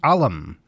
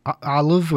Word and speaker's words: Ollam: You're pronouncing it traditionally in the sense that most people do say Alam, but it's actually pronounced Olive. – Olive with a V? – Almost with a V sound, yes. – But it's spelled O-L-L-A-M.